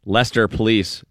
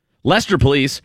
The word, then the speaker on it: Leicester: Leicester police, that's how you say it, right? L-E-I-C-E-S-T-E-R, Leicester, Mass. Isn't that how you say it? Leicester police said a shoplifter covered anti-theft devices at a Walmart with Play-Doh on December 11th.